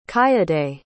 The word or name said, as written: kayode